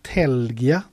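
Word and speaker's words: Tælgia: Eh, SVT eh, på fredag, nu kommer Telgia, tror jag det uttalas, Ta-elgia stavas det, eh, mhm.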